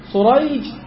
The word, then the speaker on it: سريج: أو الثاني سريج وشريح، شريح روى عن علي، شريح وسريج النطق مختلف، الحروف واحدة.